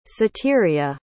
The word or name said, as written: Ceteria